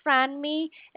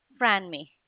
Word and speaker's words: Franmi: My name is Franmi, it's spelled F as in Frank, R-A-N-M-I, Franmi.